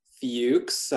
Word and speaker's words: Fuchs: It's pronounced Fuchs. I recognize the spelling for some people can be a little difficult. We'll just get the elephant out of the room right now. – It's written like Futches. That's what some people pronounce it differently, and that's okay. – Yeah. Sometimes a C and an H, you know, they don't make that sound.